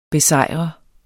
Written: besejrer

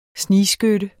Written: snigskytte

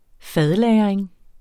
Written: fadlagring

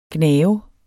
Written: gnave

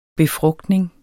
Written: befrugtning

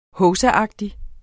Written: hovsaagtig

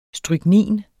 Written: stryknin

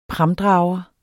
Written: pramdrager

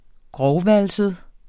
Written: grovvalset

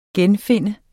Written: GENFINDE

